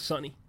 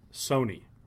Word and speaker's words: Sony: I only learned how to say his name, like, halfway through the season. I thought it was Sonny. – Sony. – Yeah. Like gotcha. Like the Walkman.